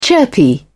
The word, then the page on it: chirpy: British English